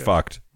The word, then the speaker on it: FUCT: Got to be pronounced fucked, right? That is the brand. – F-cut. Okay. – No, F-U-C-T.